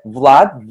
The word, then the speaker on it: Vlad: First name Vlad V L A D.